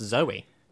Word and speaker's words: Zowie: I'm willing to accept that I'm wrong, but Duncan Jones was originally called Zowie Bowie, and it's spelled Z-O-W-I-E. And I think you pronounce that, there's no way that's Zoe. – Wow. – That's Zowie. And so I thought it's deliberately named him, you know, so it rhymes. – Yeah, but he did name him so it rhymed, but it's pronounced Zoe.